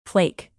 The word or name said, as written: flake